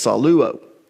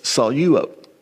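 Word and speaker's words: saleuo: Normally, when you're pronouncing Greek, koinonia Greek, you actually do the syllables according to the consonants. So many people will pronounce this saluo. But because there's a diphthong there with epsilon, epsilon, you take the lambda and you put it with the first syllable, okay? So it's saluo.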